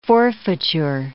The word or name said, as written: forfeiture